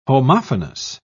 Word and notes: homophonous: British English

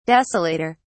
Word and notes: desolator: British English

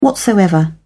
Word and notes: whatsoever: British English

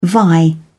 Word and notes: vie: British English

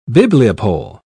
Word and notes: bibliopole: British English

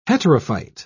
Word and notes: heterophyte: British English

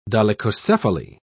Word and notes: dolichocephaly: British English

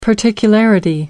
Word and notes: particularity: American English

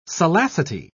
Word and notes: salacity: British English

